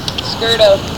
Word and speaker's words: Scurto: Scurto. – How do you spell that? – S-C-U-R-T-O.